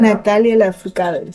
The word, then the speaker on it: Natalia Lafourcade: Entonces, vamos a escuchar una canción de Natalia Lafourcade. ¿Cómo se dice Natalia? – Natalia Lafourcade, sí.